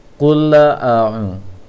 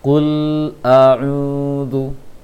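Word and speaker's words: Kul a'udhu (قل أعوذ): Bacanya seperti kulla a'un, kulla a'un. Seharusnya dialirkan suara lam. Kul a'udhu, kul a'udhu.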